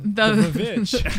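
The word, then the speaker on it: The VVitch: The Witch is my number three. – Oh, you're pronouncing it wrong. – The Vavitch.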